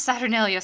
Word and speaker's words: Saturnalia: Early Romans celebrated Saturnalia around the solstice in honor of the god of agriculture. – You pronounced Saturnalia so perfectly.